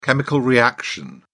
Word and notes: chemical reaction: British English

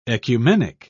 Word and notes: ecumenic: British English

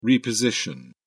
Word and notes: reposition: British English

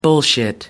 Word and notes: bullshit: American English

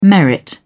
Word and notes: merit: British English

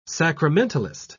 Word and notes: sacramentalist: British English

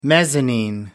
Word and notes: mezzanine: American English

